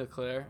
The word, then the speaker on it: Leclerc: Delaney Leclerc. – Del- or actually, it's pronounced Delaney Leclaire. It's like Delaney Leclaire. – It's Delaney okay. Leclaire.